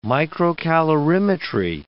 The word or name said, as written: microcalorimetry